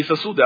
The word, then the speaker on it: сосуды: Именно не сосуды, а сосуды, окей? Ударение на «о».